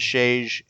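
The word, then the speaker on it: Sejić: But I believe the correct pronunciation is Sage or something of that sort. There was an interview that Jonah did for CBR TV at Comic-Con that had both of them. And he pronounced it as Shage, and they seemed to nod in agreement if he got it close enough.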